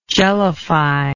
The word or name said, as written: jellify